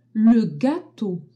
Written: gateau

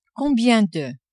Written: combien de

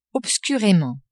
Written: obscurement

